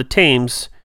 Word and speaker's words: Thames: I mean, it's Thames, but whatever. – Thames, you know. See, I don't do foreign languages very well. – Well, this one isn't. – It's quite close enough. – Anyway. – We speak American and they speak they speak. I barely do my language. – I'm not going to bicker and argue about how an A should be pronounced, especially when followed by an M. – Yeah. – Like, you know, but apparently it's with an E sound.